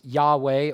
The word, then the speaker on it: Yahweh: In Hebrew, it's Yahweh, or at least that's how we pronounce it. There are no vowels in the divine name in the Hebrew text.